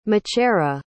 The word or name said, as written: machera